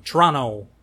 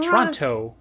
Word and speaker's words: Toronto: So Double Eyelid is a mixture of glam rock and kind of gothic post-punk band from Toronto. Not Toronto. Toronto. – Toronto. – That's how the people of Toronto pronounce it. – Toronto. – Nope. Toronto. – I know. But we say it Toronto.